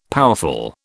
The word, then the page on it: powerful: British English